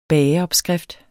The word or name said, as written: bageopskrift